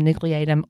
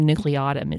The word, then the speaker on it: nucleatum: It's Fusobacterium nucleatum or nucleatum. It's pronounced both ways.